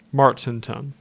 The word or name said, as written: Martinton